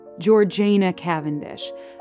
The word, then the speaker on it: Georgiana Cavendish: Now, if you are a frequent listener of this podcast, you may remember our two-part series on the Duchess of Devonshire, Georgiana Cavendish. And yes, I promise, that is how she pronounced her name.